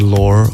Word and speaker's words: lore: L-O-R-E.